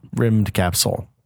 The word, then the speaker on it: Rymdkapsel: It's not how you would think it'd be spelled. – Rimmed Capsule. It was made by one dude,